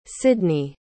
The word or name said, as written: Sydnee